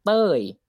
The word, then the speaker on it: Toey: So it's called Toey, T-O-E-Y, right? It's like Tony, but you change the N to E.